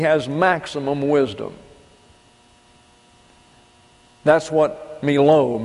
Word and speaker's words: melo: M-E-L-O, pronounced Melo.